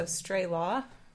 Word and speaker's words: Strelow: So Strelow, like stray dog and Law and Order.